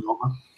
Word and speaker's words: Oehme: Yeah, Stefan Oehme. – O-E-H-M-E. You know, it's O-E as in O-Umlaut, presumably, right? In German? – Yes, German, yeah. – But Oehme, I mean, back when I was in school and dinosaurs roamed the earth, I took a smattering of German.